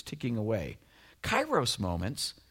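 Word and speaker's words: kairos (καιρός): It's pronounced kairos.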